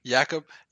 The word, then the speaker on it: Jakub: Is it Jacob? Is it Jakub? – Jakub Vrana.